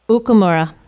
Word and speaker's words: Okumura: The last name is pronounced Okumura. – Okumura. I had the accent on the wrong place.